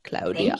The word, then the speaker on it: Claudia: As I'm owning my voice, I need to own the Italian pronunciation. – Oh, I love it. Yeah. It's very sexy, Claudia.